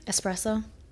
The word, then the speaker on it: espresso: Yeah, you say it right. I don't know why, but people say espresso. – Oh, I know. – And I think it's the funniest thing. Like, it used to annoy me. And I am like, clearly there's an S. It's spelled with an S.